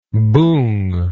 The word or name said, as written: boong